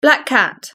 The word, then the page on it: black cat: British English